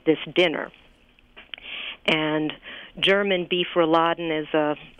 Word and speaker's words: Rouladen: Yeah, Rouladen.